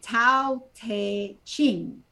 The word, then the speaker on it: Tao Te Ching: And that's by Tao Te Ching.